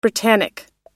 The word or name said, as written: britannic